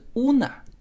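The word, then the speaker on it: Una: 实际上它的这个 U 啊是发 U 的音。是乌娜，那么乌娜这个词就非常非常多的韩国女孩取这个名字，所以因为又长得是东亚的面孔嘛，所以当时就很多人以为 Yuna 是就叫乌娜的时候，以为她是韩国人啊。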